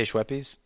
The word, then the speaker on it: Schweppes: Do we have to say Schweppes? – Yeah, that's how it's pronounced. I mean.